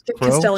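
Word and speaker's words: Crowe: Am I saying that right? I don't play Grey Knight. – Crow? Castilian Crow.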